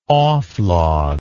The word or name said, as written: oflag